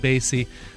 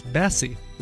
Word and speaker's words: Bassey: He wanted to correct me on my pronunciation last week of Shirley Bassey. I said Bassey. It's Bassey, apparently.